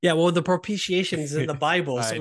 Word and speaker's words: propitiation: propitiation for our sins.